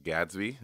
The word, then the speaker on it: Gadsby: So then do you like some of these comedians recently, like your Drew Michaels, your Hannah, is it Gadsby? Is that how you say her name? – Yes, that's how you say it.